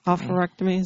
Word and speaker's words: oophorectomy: Oophorectomy, is that how you say it? – Yeah.